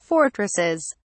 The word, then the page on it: fortresses: British English